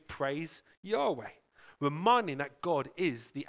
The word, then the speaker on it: Yahweh: Kind of the closest translation we've got in this in Hebrew is YHYH, or pronounced Yahweh.